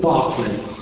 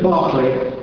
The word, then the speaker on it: Berkeley: He studied at what you quaintly call the University College Berkeley, which we in England would call the University College Barclay. How we in England get to pronounce B-E-R-K, Bark, when it's clearly should be B-A-R-K, and you pronounce it Burke, and we think you're odd.